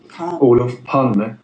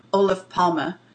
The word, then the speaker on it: Olof Palme: Olof Palme. – Olof Palme.